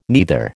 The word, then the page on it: neither: American English